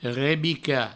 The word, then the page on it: rebicar: rebica